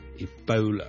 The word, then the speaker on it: Ebola: 最近如果大家有看國際新聞，都應該會留意到 Ebola 這個伊波拉病毒在某些地方肆虐。 Ebola 伊波拉病毒，我們雖然廣東話我們譯作伊波拉，波拉我們是 O 的一個發音，但如果是英文，我們通常是讀 Ebola 的 Ebola，煲煲，好像弓箭的弓那個煲、 okay?